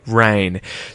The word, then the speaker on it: reign: Rain. So, it's spelled here R-E-I-G-N. Rain, in this case, is a verb, and it means to hold royal office, to rule as a monarch.